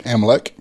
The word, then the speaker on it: Amalek: So Joshua did what Moses had said and fought the army of, I think I'm saying it right, Amalek? – Amalek.